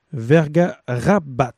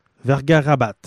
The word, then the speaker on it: Bersuit Vergarabat: On vient d'écouter une pièce de Bersuit Vergabarat. Non, je recommence. Une pièce de Bersuit Vergarabat. Vergarabat.